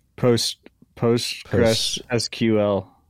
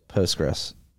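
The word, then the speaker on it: PostgreSQL: They moved off of Google's database to, how do you pronounce it? – Postgres SQL. – Yeah, Postgres.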